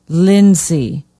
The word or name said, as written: Lindsey